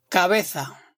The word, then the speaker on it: cabeza: The Z is pronounced like the TH in the English word think. So instead of cabeza, you'll hear cabeza.